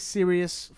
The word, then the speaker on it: Sirius: It's IK Sirius Football. – Is that spelled like Sirius Black from Harry Potter?